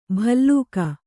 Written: ಭಲ್ಲೂಕ

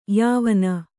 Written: ಯಾವ

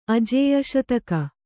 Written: ಅಜೇಯಶತಕ

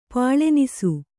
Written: ಪಾಳೆನಿಸು

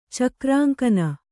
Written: ಚಕ್ರಾಂಕನ

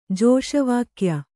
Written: ಜೋಷವಾಕ್ಯ

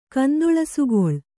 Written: ಕಂದೊಳಸುಗೊಳ್

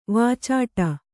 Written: ವಾಚಾಟ

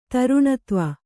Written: ಧಾರಿಣೀಧವ

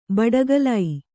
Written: ಬಡಕಲು

